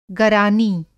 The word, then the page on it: गरानी: Hindi